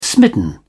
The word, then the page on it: smitten: British English